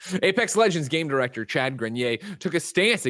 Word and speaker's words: Grenier: If it's French, then it's Grenier. If it's not French, I would say Grenier. – Okay. But I can't tell. Says Greg said it right.